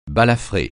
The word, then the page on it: balafré: francais